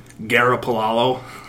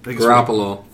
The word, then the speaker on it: Garoppolo: Well, Garoppolo. – Oh, Garoppolo.